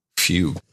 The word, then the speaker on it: fugue: Fugue. Hmm. Do we pronounce it fugue or fuge? – Pretty sure it's fugue.